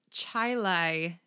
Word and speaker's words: Chili: Contrary to the spelling, she grew up in Chilai, New York. It's spelt like chili.